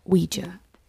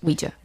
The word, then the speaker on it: Ouija: Oh, and then they're talking about who believes in God, and they get on the subject of, how do we say it? – Ouija. – Ouija. You Googled it? Or that's how Brandon said to say. – That is how it is pronounced. – Ouija.